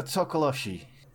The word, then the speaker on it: Tokoloshe: You know what? A Tokoloshe. – Yeah, that's actually good. Good one.